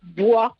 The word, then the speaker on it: bois: C'est-à-dire qu'on ne va pas la prononcer comme dans le mot bois. Le bois s'écrit B-O-I-S et le S, on ne l'entend pas.